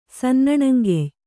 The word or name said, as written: ಸನ್ನಣಂಗೆಯ್